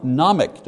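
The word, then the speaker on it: gnomic: Number one, nomic, don't pronounce the G, nomic, meaning knowledge.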